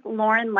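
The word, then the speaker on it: Lorin: Hi, this is Lauren Lappin, L-O-R-I-N-L-A-P-P-I-N.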